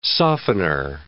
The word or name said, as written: softener